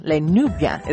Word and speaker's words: Les Nubians: Maqueta, Nominated sisters Helene and Celia Fusar, Les Nubians, Les Nubians is how you pronounce it, and that's who they are.